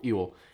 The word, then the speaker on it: Ewell: Ewell. – Ewell. And Lieutenant General A.P. Hill. So they kind of split from two to three. And Ewell and Hill, they had previously served under Jackson, but they had never really served as corps commanders before.